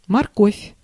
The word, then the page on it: морковь: /mɐrˈkofʲ/